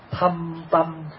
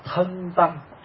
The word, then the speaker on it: thâm tâm: Tương thử thâm tâm phụng trần sát. Thì có nhiều vị đọc là thân tâm, không đúng.